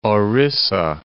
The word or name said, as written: Orissa